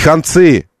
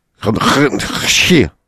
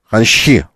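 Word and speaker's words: Ханчи (红旗): Китайский производитель премиальных автомобилей Ханчи, мы выяснили, как это произносится, между прочим, не ханцы, э, не, не концы никакие, э, которые в воду, нет. А это буква Ч, она такая, как бы, э, китаисты, если бы были здесь, они бы сейчас сказали. Она как «ха» и как «че» вместе. «Чи». А, хан... Ханче. Ханчи.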